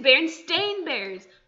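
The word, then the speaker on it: Berenstain: And it's not even spelled with an E. It's spelled with an A. And it's Baron Steen Bears.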